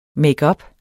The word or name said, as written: makeup